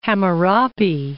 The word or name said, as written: Hammurapi